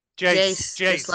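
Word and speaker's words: Geyse: In fact, correct me, is it Geyser or Jazer? How do you pronounce that? Jace. – Like all right. – Yeah. – Jace, all right.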